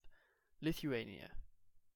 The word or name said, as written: Lithuania